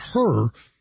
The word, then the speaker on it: Hur: I know it's spelled H-U-R, but it's pronounced her.